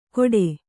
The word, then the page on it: ಘೋಡೆ: kannada